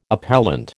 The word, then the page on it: appellant: American English